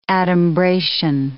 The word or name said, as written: adumbration